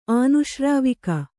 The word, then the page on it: ಆನುಶ್ರಾವಿಕ: ānuśrāvika